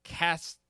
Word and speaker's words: Cassel: And Seymour Castle. Kissel. This is John Cassavetes. – I think it's Gazzara. – Cazel. What, you know what? – Gazzara. – It's Cassel.